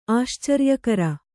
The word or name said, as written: ಆಶ್ಚರ್ಯಕರ